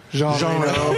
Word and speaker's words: Jean Reno: Jean Reno. Did I say that right? – Jean Reno, but...